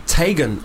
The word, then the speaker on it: Teigen: Chrissy Teigen is actually meant to be pronounced Tegan or Tigan.